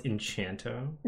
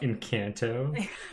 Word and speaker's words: Encanto: I thought it was Enchanto, not Encanto. So I googled it really quick.